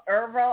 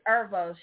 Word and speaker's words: Erivo: But I did get a chance to see Color Purple on Broadway, but I seen it when Cynthia Erivo, is that how you say her name? – That sounds right. That's close. – I think that's Erivo. – I think it's Erivo.